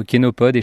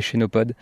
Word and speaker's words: chénopode: J'entends toujours les deux versions et du coup, de mon côté, j'alterne un petit peu entre les deux, entre kénopode et chénopode. Ça s'écrit chénopode.